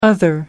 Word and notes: other: American English

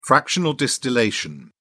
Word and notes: fractional distillation: British English

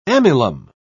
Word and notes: amylum: British English

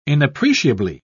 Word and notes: inappreciably: British English

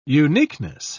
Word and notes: uniqueness: British English

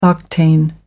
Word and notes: octane number: American English

